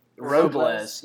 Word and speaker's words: Robles: Robles. Y'all don't even say his name right.